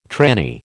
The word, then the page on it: tranny: American English